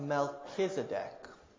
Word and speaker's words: Melchizedek: Jesus is a high priest after the order of Melchizedek, is how we pronounce that word.